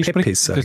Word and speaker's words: Pepys: Man schreibt ihn P-E-P-Y-S. – Ah. Warte, P-E, was? P-E? – P-E-P-Y-S. – Ah, und das also P Peppis spricht man gar nicht, das P. Hm. – Doch, beide. – Also, stimmt, man spricht ja das Y nicht.